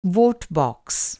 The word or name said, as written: वोट बॉक्स